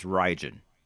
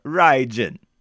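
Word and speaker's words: Rhyging: I think it's Rijin. – Okay. – Or Rijin. Okay? – Fair enough. – That's R-H-Y-G-I-N-G.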